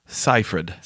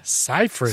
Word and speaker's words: Seyfried: It is Amanda Seyfried. – Seyfried. – So you say Cy. Cy is the first one.